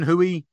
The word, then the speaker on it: Hooey: Kevin Hooey has reached out to us. I think is a pretty new listener. – And that rhymes with Bowie.